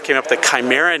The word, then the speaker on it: chimera: That happened to me until somebody told me it was Chimera. You know, that's the way it's pronounced.